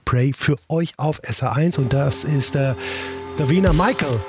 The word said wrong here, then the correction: Davina Michelle: Davina Michelle, Entschuldigung, so spricht man sie aus.